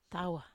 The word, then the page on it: tawa ii: Wamesa